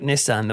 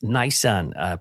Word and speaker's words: Nisan: Yeah, I know on paper it looks like Nissan, the make of car, but here, as far as I know, it's Nisan, pronounced a month in the Hebrew calendar.